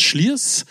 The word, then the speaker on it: Slyrs: Also schreibe Slyrs, S-L-Y-R-S, aber das Schliers, ganz einfach bayerisch, sitzt am Schliersee am schönen, im schönen bayerischen Alpenvorland.